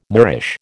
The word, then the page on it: Moorish: American English